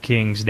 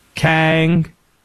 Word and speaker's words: King: that is spelled like Michael King's name. K-I- N-G. Do the people from this place in North Carolina say King, North Carolina? No, they do not. Kang. – Kang. – That's it. It's Kang, North Carolina.